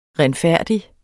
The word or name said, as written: renfærdig